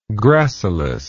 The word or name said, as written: gracilis